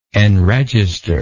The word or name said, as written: enregistration